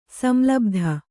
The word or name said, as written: ಸಂಲಬ್ಧ